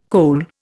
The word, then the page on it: cole: Indian